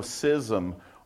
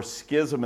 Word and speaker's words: schism: 1 Corinthians chapter 12, verse number 25 said that there should be no schism or schism in the body. I think that word is actually pronounced correct both ways, schism or schism.